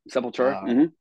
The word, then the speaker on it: Sepultura: Yes, and I read where I think it was Max Cavalera of, I'm saying his name right, of Sepultura, That's right, Sepultura.